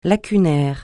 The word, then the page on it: lacunaire: francais